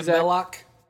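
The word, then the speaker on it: Belloq: Yeah, Belloc.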